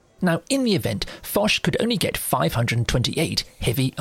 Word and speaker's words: Foch: So that's spelled F-O-C-H, but it's a Breton name, so it's pronounced Foch.